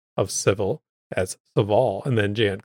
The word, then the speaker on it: Sevil: JN, my lore savior, put out a tweet saying Sevil is pronounced like devil. Just swap the D with an S.